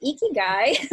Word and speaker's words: Ikigai: Ikigai is pronounced like with a smile. Iki and gai.